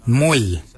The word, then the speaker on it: mooly: Warrigal is spelled W-O-R-R-A-G-O-L. Warrigal. Warrigal is your upper mouth. For your nose, we say moil. Moil is your nose. Moil is spelled M-O-O-L-Y.